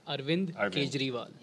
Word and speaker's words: Arvind Kejriwal: yes, How do you pronounce his name? – Arvind, Arvind. Kejriwal.